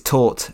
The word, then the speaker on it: taut: I think it's taut rather than tout.